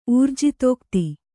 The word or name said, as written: ಊರ್ಜಿತೋಕ್ತಿ